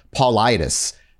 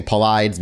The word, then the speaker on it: Paulides: And this is how David and I'm gonna I apologize for mispronouncing his name last time. His his last name is Paulitis. David Paulitis. I kept saying Paulides because it's just how it's phonetically spelt, and that's my own brain doing its own thing.